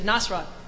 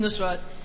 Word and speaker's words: Nusrat: Because, no, because I have a friend named Penny, and she, I played her the thing, and she said, Nasrat. – Yeah. – It's Nusrat, and he's my Elvis.